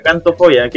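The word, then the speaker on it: xantofobia: es con X, es con X, pero se pronuncia cantofobia, que es el miedo, ah.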